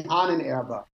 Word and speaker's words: Ahnenerbe: He has a segment of the SS, and you can correct me on the pronunciation, the Ahnenabe? Is that how you say it? – The Ahnenerbe.